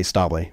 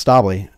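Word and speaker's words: Stäubli: I would say Staubli, but it's a Swiss family business. – Pronounce it again. – It's Staubli. And it's Staubli, but if you're in Switzerland, they would say Staubli.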